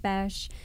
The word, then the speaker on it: Boesch: Like Carmen said, the O is silent. And I'm married. My husband works here as well, Mike Boesch.